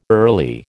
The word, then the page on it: early: American English